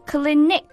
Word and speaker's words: Clinique: 是的，我们经常可以在各大护肤品网站上看到倩碧的广告，也有很多年轻的妹子呢，现在都特别喜欢这个牌子倩碧 Clinique C-L-I-N-I-Q-U-E Clinique。 – 哎，其实跟这个诊所的发音差不多是吧？